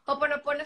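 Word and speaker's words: Hoponopono (Hoʻoponopono): Uno de los que me da mucha curiosidad es el de Oponoporo. Creo que se dice así. Sí, bueno, Hoponopono es una herramienta eh,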